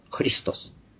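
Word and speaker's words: ハ リ スト ス、 (Христос): これはロシア語ではフっていう強音に、息のコーで、ハリトス、ハリストス、ハリストスっていう形になります。ハリストス教会、聖教会と言いますか。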